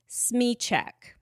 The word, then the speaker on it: Smyczek: This is how the chair umpire at this particular match pronounced it. His name is Tim Smychek. His last name is spelled S-M-Y-C-Z-E-K.